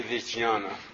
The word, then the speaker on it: Vijnana: That's a V at the beginning. Vijnana, say Vijnana. Bhairava.